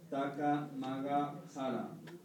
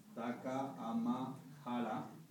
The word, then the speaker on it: Takamahara: Entonces, es muy importante prestar atención en no pronunciar Taka Maga Hara. Esto es una oración sintoísta que lo pronuncia así, porque este tipo de pronunciación enlaza nuestra oración con divinidades que están a un nivel mucho más bajo. Entonces hemos de pronunciar bien Taka Ama Hara.